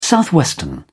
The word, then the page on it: southwestern: British English